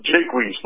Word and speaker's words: Jacquet: It's close, Bill. It's Jacquet, long A.